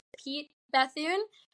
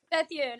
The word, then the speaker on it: Pete Bethune: Jake's here too, and we have special guest Pete Bethune.